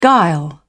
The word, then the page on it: guile: British English